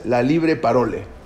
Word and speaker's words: La Libre Parole: En 1892, el mismo Drummond, este periodista antisemita, empezó a publicar un periódico totalmente antisemita, se llamaba, a ver cómo se dice en francés, La Libre Parole.